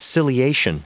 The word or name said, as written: ciliation